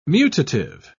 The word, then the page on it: mutative: British English